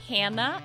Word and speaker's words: Hanna: Follow me on Instagram at Hanna, H-A-N-N-A, underscore. My last name is spelled L-J-U-N-G-H-O-L-M.